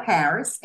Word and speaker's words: Harris: So E T H E L Harris, H A R R I S.